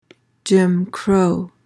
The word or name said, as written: jim crow